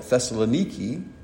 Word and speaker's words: Thessaloniki: the Greeks would call it Thessaloniki, but we would, in English, typically pronounce it Thessalonica.